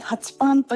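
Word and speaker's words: ∞パン: おで、はい、次がちょっと私読み方がわからなくて無限大にパンってうん、そうで、ハチパンと読みます。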